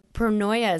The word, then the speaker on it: pronoia: But what in the world is pronia? Am I even saying it right? – Pronoia is how I... – Pronoia. – Yeah.